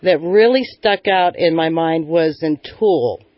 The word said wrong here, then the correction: Tooele: Tooele? Is that how you say it? – Tooele, Utah.